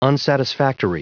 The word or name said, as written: unsatisfactory